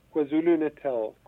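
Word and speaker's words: KwaZulu-Natal: Yes, KwaZulu, KwaZulu Natal.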